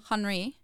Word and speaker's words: Henri: Henri. – No, Henri. Not huh. Henri.